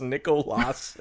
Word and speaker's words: Nicholas: Yes, it's pronounced Nicolas, it turns out. Nicolas. – Okay. – The moles, M-O-L-E-S-K-I-N-E.